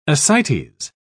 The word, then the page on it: ascites: British English